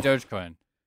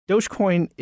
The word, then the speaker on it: Dogecoin: And the most obvious choice was this cryptocurrency that was quite popular back then, and it's still pretty popular, I think, called Dogecoin. Is that how you would pronounce it? – Yeah, Dogecoin. – Yeah, Dogecoin